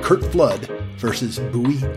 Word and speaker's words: Bowie: Kurt Flood was a very good Major League Baseball player, and Bowie Kuhn, and that's Bowie, like the Bowie knife, and Kuhn, but it's spelled K-U-H-N.